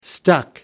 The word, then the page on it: stuck: American English